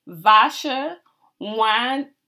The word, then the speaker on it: Varsha Wadnikop: In conversation with me today is the fabulous Varsha Wadnikop.